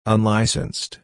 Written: unlicensed

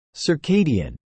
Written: circadian

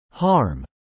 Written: harm

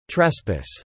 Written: trespass

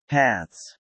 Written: paths

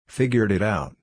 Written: figured it out

